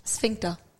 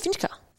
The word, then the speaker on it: sphincter: I thought it was Finsker. Finsker? It's F-I-N-S-K-A. – Yeah, sphincter is your asshole, but that's okay. – I was like, I've been saying it wrong this whole time.